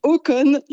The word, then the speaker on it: Håkon: Alors c'est Håkon 8. – Håkon 8, il a 53 Exactement. – Ans, bon, il a eu le temps d'être préparé à ce rôle. – Oui, tout à fait. Håkon a été donc prince héritier à même pas 18 ans.